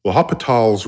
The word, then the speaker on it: L'Hôpital: But there's a rule in calculus called L'Hôpital's rule, L'Hôpital, and it's almost spelled like hospital, but it's L'Hôpital.